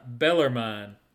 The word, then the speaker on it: Bellarmine: Well, there's a team that was new to their conference, and I don't even know if I'm pronouncing this right. Bellarmine is the way it's pronounced.